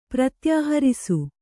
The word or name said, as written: ಪ್ರತ್ಯಾಹರಿಸು